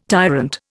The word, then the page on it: tyrant: Indian